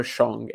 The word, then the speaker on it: Zhang: So I am still expecting some pretty big things from Zhang this year. It's just that, or Shang, if I want to say it like Matthew is trying to teach us to.